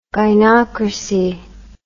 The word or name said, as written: gynocracy